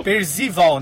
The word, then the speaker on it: Percival: É Percival? Percival? – É Percival. – Percival, né? – É, escreve-se Percival, assim como se escreve Arte 3Miss. A pronúncia certa é Percival.